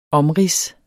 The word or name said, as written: omrids